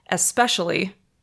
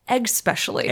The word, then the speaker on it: especially: So when you say the word especially, you're saying it exactly how it's spelled, right? – Especially. – Especially. That's especially how it's pronounced.